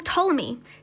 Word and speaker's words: Ptolemy: Most importantly, his general Ptolemy, spelt P-T-O-L-E-M-Y, with a silent P just to make it fun, conquered Egypt, where he began the Ptolemy dynasty as King Ptolemy himself.